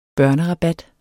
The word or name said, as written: børnerabat